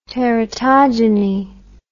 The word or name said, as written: teratogeny